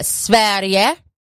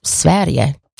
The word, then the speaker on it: Sverige: Really, really good stuff from Sverige or Sweden. That's how you say it. That's how you say Sweden in Swedish, in Svenska. Yeah, it's Sverige. Jag älskar Sverige.